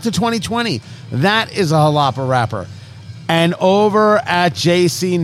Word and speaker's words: Jalapa: Jalapa, J A L A P A, pronounced Jalapa. It's a region in Nicaragua.